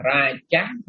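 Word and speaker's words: rāja: Chữ đó mình đọc là raja ha. Đọc là raja, nhưng đọc raja, raja. – Raja.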